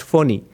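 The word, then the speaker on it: Fani: Most people pronounce it funny, but it's supposed to be pronounced phony.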